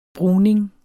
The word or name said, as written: bruning